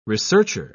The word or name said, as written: researcher